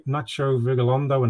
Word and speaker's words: Nacho Vigalondo: Apologies for my rubbish pronunciation, but it's directed by Nacho Vigalondo. And again, apologies. – No, you got that one right.